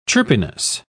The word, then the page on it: chirpiness: British English